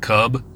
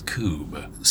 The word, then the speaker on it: Kubb: If you want to look it up, it's called Coob. I think it's spelled K-U-B-B. Looks like cub, but it's Coob.